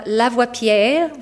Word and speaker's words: Lavoipierre: And also, we're going to welcome tonight two very special guests, Dr. Bronwyn King, AO, and Angela Lavoipierre, which I pronounce with an impeccable French accent.